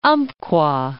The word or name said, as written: Umpqua